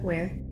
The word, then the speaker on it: Weir: Andy Weir. I don't know how to pronounce the last name. How do you pronounce it? – Yeah, you got it. Weir.